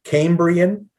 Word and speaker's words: Cambrian: Cambridge. – Cambridge. I'm saying it wrong. – Cambrian. – Cambrian. – C-A-M. Cambrian. There we go.